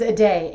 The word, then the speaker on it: Aday: My name is Aday, A-D-A-Y, Aday.